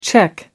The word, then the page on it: czech: American English